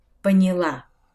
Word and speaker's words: поняла: Вторая установка – Запомните, что в глаголах женского рода прошедшего времени ударение падает на последний слог. Например, подняла, восприняла, поняла.